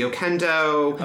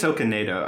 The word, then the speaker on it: Okonedo: I think it's Okonedo. – Okonedo? – I looked it up because I want to remember how to pronounce it, and I feel like I have three different pronunciations. – Yeah. – So I'm just going to say, like, Okonedo.